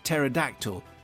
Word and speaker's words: pterodactyl: but they won't hear my pet pterodactyl using the loo. Do you know why? Because no. – The P is silent.